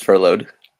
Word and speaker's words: furloughed: the word the word is pronounced furloughed. Furloughed. Thank you.